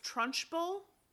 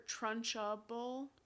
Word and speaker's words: Trunchbull: He says Trunchbull. – Yeah. – And I always hear Trunchable, and I know that's not how it's spelled. – It's not how it's spelled or pronounced.